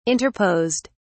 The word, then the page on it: interposed: British English